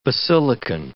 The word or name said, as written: basilican